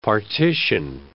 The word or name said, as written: partition